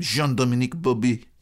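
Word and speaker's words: Jean-Dominique Bauby: which is how they say it. I very much like how he says his name, Jean-Dominique Bobby.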